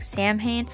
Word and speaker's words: Samhain: It's spelled S-A-M-H-A-I-N, so it's like Samhain, but it's pronounced Samhain. Samhain was the first day of November, and the Celts would celebrate the start of winter.